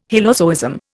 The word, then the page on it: hylozoism: Indian